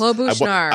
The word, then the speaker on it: Klobuchar: See, that's Klobuchar. – I want to put the accent on the second. The accent is on the first, but yes.